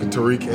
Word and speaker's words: Terique: And I'm on Facebook, Terique Epps, and my first name is spelled TERIQUE, last name EPPS.